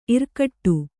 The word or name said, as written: ಇರ್ಕಡೆ